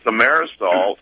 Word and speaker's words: thimerosal: And I believe I'm pronouncing that correct. – Right. – Is that right, Doctor? – Yeah. – Right. – Mm-hmm. – Right. – And the thimerosal is the mercury derivative preservative.